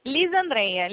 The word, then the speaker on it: Lisandreia: Lisandra, como que é? Desculpa, falei errado, né? – Lisandreia.